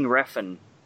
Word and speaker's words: Refn: Uh, how do you say his name? Nicolas Winding Ren. – Nicolas Winding Refn.